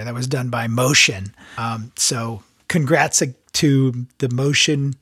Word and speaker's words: Mocean: So you kind of change your name, but they changed your name to Motion. It's spelled MOCEAN. So like the ocean, but with an M on the front of it.